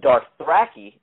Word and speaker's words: Dothraki: Khaleesi, the mother of dragons, escapes on the previous episode on her dragon, but now she's in the middle of nowhere, and the Dothraki, is how you pronounce it, surround her now.